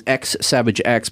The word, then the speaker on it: xSavagex: And so if you're looking for this band, they are the way you can spell it is xSavagex, but the X's are silent, and you can just check them out.